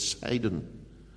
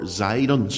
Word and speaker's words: Sidon: And then there's the city of Sidon, or Zidon, spelt with a Z as it's sometimes pronounced.